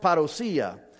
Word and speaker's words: parousia: It's actually pronounced parousia.